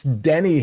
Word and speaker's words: Denny: It's Denny Hamlin, D-E-N-N-Y.